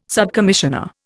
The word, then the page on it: subcommissioner: Indian